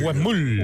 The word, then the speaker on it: Huemul: Creo que se pronuncia Huemul. – Huemul. – Huemul.